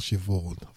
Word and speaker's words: Siobhan: Actually, it's called Siobhan. That's the way it's spelled. S-I-O-B-H-A-N. Siobhan.